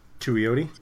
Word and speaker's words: Tuioti: T-U-I-O-T-I? How would you say that? – Tuioti? – Tuioti, there we go. Yeah, I was trying to stick in an extra vowel there.